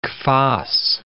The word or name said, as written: kvass